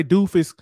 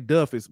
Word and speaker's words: Duffus: I don't want to say Doofus because that's what it looked like, but it's, I'm gonna say Duffus.